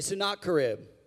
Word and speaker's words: Sennacherib: Everyone say Sennacherib. Now, that's a very hard name. It's not spelled like it's pronounced at all, okay?